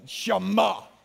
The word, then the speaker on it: Shema: Now, you almost got to say it like you're coughing up something. Shema.